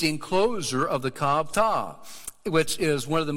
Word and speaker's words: Ptah: Memphis was believed to be under the protection of the god Ptah. And maybe it's more like a silent P on the front of that Ptah.